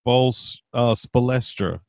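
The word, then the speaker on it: Spoelstra: Pronounce Spolstra for me twice. – Spolstra. Spolstra, Spolstra, Spolstra.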